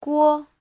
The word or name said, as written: guo5